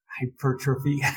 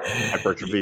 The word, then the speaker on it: hypertrophy: hypertrophy. I never can pronounce that word. – Hypertrophy.